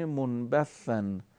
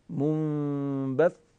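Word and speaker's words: منبثّا: مثلا کلمه منبثن، اگر این صاد دو تیکه بشه، مثلا در این صورت که من تلفظ میکنم، به این صورت که تلفظ میکنم، منبث، این قطع شدن اشکال داره. صوت در تلفظ این حرف در حالتی که تشدید داره ادامه داره، منبث، یه مقدار من میکشم که شما بهتر متوجه بشین.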